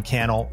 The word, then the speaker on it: Cannell: My name is Sean Cannell, rhymes with YouTube channel.